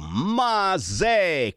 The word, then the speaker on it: Masè: Sono appena uscita la nuova canzone di Mauro Masè con l'accento sulla E, come Bob De Niro.